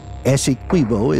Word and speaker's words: Essequibo: Essequibo is pronounced with the Q-U-I like in quit. It's an Amerindian word, and although it looks Spanish, it is not pronounced that way.